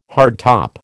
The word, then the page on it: hardtop: American English